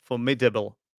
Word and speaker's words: formidable: And Noop said we were just formidable. So I'm just going to quickly check. I was just checking the pronunciation. Formidable. Yeah, that's correct. So the stress is on the second syllable, formidable.